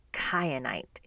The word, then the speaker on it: kyanite: The first crystal up on my uh, list is kyanite. It's spelled with a K, K Y A N I T E, kyanite.